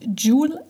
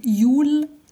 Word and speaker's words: Yule: I somehow thought it's pronounced Jule lads, and I did it for the past, I don't know, 3 years. But I will correct it. It's actually Yule lads.